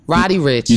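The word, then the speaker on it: Roddy Ricch: I think it's Rudy Rich. – Okay. – Roddy? Roddy Rich.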